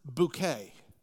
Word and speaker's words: Bucket: The main star, her name is Miss Bucket, or as she pronounces it, Bouquet.